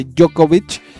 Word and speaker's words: Djokovic: Y en este caso hablamos de Djokovic. – Así se pronuncia.